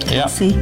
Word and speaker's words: Kelce: Meanwhile, Taylor Swift and Travis Kelce, is that how you say it? – Kelce, yeah.